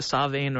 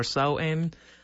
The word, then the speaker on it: Samhain: The biggest, if you will, is one anglicized pronounced Samhain, properly pronounced Samhain or Samhain. Yeah, Gaelic is a weird language.